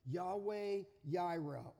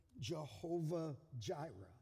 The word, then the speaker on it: Jehovah Jireh: Yahweh Jireh, or as we pronounce it, Jehovah Jireh.